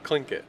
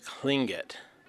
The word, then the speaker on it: Tlingit: It's how I've always learned growing up. Grandma, mom, uncles, they always said it like that. Tlingit. – The name is Tlingit.